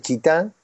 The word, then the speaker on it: Chita: Entonces bueno, me desvié hasta la ciudad de Chitá, se escribe así como si fuera Chita, pero sin el acento en la A.